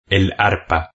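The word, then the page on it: el arpa: Spain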